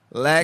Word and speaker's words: lekker: Lekker.